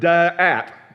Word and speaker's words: da'at: It's the word da'at. Da'at. Da'at. Okay? Everybody say dat. Everybody say that's what you need. That's what you need to get a hold of, okay? It's actually two syllables, but I'm redneck, so I can put it in one.